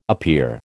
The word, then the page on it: appear: American English